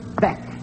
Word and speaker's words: Bech: Who is Joseph Beck? – Can you spell that, please? – B-E-C-H. Joseph Beck. B-E-C-H.